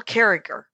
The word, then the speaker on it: Carriger: Her name is Gail Carriger. And it's spelled Gail Carriger, but she goes with a hard G on the second G.